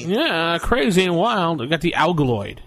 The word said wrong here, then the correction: Algoid: That's how I pronounce it. Am I pronouncing that right, Jason? – No. This is how many L's did you just put in it? – Algoid. – There you go. – Algoid. – Like Altoids.